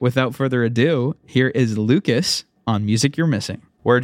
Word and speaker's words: l.ucas: Also, his name is pronounced Lucas, not l.ucas.